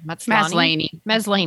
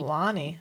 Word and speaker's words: Maslany: How do you say her name? – Maslany. – I think it's Tatiana Maslany. – Maslany. – You're right.